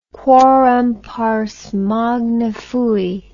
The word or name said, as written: quorum pars magna fui